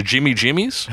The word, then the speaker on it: Gimme Gimmes: Let's talk about Me First, and I think it's a soft G on this, the Jimmy Jimmies.